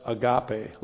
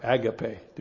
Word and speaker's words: agape: That's agape love or agape, depending again how you want to pronounce it, love.